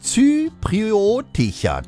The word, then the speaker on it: zypriotischer: Und musikalischerseits der britisch-zyproidische, ich stolper da schon letzte Stunde drüber. Wie spricht man das aus? Zypriotischer. Das geht doch mit dir gerne. Du musst nur langsam sprechen, siehste.